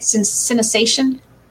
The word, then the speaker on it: synesthesia: Synesthesia.